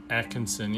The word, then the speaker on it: Atchison: Okay, the Sallie House was built in Atkinson. I think that's how you say it, right? Atkinson? – Atkinson, yeah.